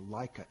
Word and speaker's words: Laika: I will just tell you, who the first dog in space was a Soviet era dog called the Laika. L-A-I-K-A is how you spell it in English.